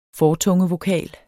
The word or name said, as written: fortunge